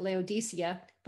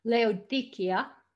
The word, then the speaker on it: Laodicea: I know up until now I used to pronounce it Laodicea, but apparently the true pronunciation is with a cut Laodicea.